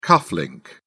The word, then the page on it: cufflink: British English